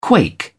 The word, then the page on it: quake: British English